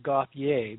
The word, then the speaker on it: Gauthier: There, and so I would have pronounced it Gauthier because it looks French to me. So it is French, and that is the correct pronunciation. We just Americanized it.